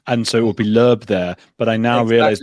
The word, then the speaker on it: Loeb: Is Loeb the right pronunciation? – Loeb, yes. Originally, Loeb. It's German, but in the US, they call me Loeb. I was born in Israel, and they call me Leib, actually.